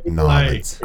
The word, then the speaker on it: New Orleans: It's pronounced Nolens.